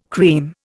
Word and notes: cream: Indian

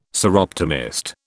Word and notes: Soroptimist: British English

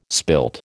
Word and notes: spilt: American English

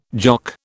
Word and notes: Jock: British English

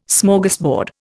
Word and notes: Smorgasbord: Indian

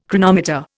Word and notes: chronometer: Indian

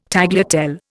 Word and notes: tagliatelle: Indian

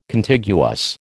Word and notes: Contiguous: American English

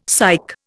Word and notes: psych: Indian

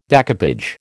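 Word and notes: découpage: American English

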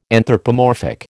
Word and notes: anthropomorphic: American English

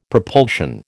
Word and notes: propulsion: American English

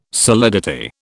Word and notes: solidity: British English

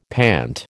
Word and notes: pant: American English

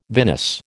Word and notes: vinous: American English

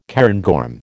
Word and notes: cairngorm: American English

